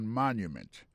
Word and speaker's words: monument: Pour mot monument, il tombe sur le premier syllabe là. The Washington Monument. Monument, c'est comme ça on dit monument en anglais.